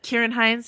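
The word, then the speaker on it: Ciarán Hinds: The villain in this film, another fun Game of Thrones tie-in, is a CGI creation called Steppenwolf, voiced by Kieran Hines. Did I get that name wrong? I hope not. And, no, that's right.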